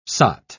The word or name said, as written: sot